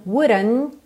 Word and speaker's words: wouldn't: Essa letra D foi americanizada, porque esse é um, um padrão típico da pronúncia americana, que é rararã. Rararã, por isso está pintadinha de azul claro. Então, wuran, wuran. O T, consoante oclusiva aqui também quase não aparece.